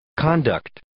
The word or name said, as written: conduct